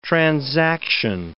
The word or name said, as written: transaction